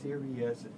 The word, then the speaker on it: Sirius: So Sirius pronounced Sirius, is the brightest star in the constellation Canis Major.